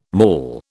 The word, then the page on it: mall: British English